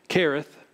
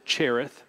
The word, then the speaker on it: Cherith: Now, Kareth, some would pronounce it Cherith. It is spelled with a C-H as you see it there, but I think the correct Hebrew pronunciation is Kareth, as if it were to say K-E-R.